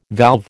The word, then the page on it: valve: American English